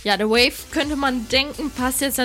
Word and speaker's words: Wayf: Die werden nämlich W-A-Y-F geschrieben, aber trotzdem Wave ausgesprochen.